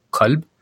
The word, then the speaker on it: Qalb: There's a programmer in Brooklyn released an Arabic programming language called Qalb, or pronounced in his dialect is called Alb.